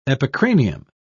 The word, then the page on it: epicranium: British English